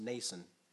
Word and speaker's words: Mnason: Also, some of the disciples from Caesarea went with us and brought with them a certain, so it's Mnason, but I think it's pronounced Nason, silent M, of Cyprus, an early disciple whom we were to lodge.